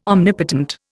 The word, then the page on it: omnipotent: Indian